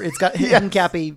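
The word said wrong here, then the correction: Hincapie: Hinkapi is how you say it, I believe.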